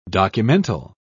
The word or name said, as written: documental